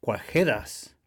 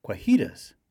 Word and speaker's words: cuajedas: Órale, the onda en Caló this week is cuajedas, alternatively spelled and pronounced cuajitas for primarily English speakers.